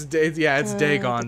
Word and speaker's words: Dagon: I think it's Dagon. – It's Dagon. Dagoth is. I'm thinking of Dagoth from fucking Morrowind. – I say. I think it's Dagon, not Dagoth. – Yeah, it's Dagon.